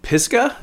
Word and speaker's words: Pisgah: This is from Pisgah, P-I-S-G-A-H.